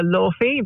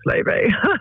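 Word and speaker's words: Laufey: I would love Promise by, some people call her Lorfi, but it's actually pronounced Levy.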